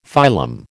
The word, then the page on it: phylum: American English